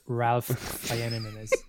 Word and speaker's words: Ralph Fiennes: It's pronounced Ralph Fiennes.